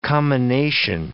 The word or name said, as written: commination